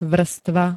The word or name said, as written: vrstva